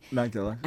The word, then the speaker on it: Magdala: Magdala.